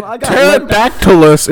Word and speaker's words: Pterodactylus: So Pterodactylus is an extinct genus of pterosaurs. – So the P is silent.